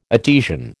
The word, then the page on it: Etesian: American English